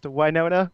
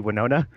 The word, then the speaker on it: Wynonna: Thing it's the best thing about it too is that it's why it's specifically pronounced Wynonna, which is weird every time they say it because in my head it should just be Winona.